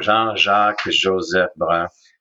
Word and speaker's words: Jean-Jacques Joseph Brun: So Jean-Jacques Joseph Brun. Translated, it'd be John Brown. So, but it's always been a challenge to pronounce it.